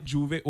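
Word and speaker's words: J'ouvert: Juvert. Juvert. You know what I'm saying? It's spelled Juvert, but it's known as Juve because it's French, right?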